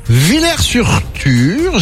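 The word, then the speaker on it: Willer-sur-Thur: Villers-sur-Tur, j'espère que je prononce bien. Tu es donc dans oui.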